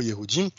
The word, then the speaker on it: Yehudim: que j'ai découvert sur le, mot Yehudim. Donc, tu prononces pas en fait euh, le J comme nous on le prononce, tu prononces en fait comme un Y, tu vois.